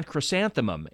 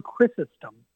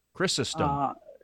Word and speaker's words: Chrysostom: And I said, I believe it's going to be St. John Chrysanthemum. And she said, I don't think that is the right pronunciation of that. So give me the correct pronunciation, if you would, please. – Yeah, well, of course, I don't speak Greek, so I also will mess this up. But it's St. John Chrysostom.